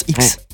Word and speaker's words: IKKS: Déjà j'ai appris que IKKS en fait ça veut dire X. – Bien sûr on dit X. – Ça se prononce X.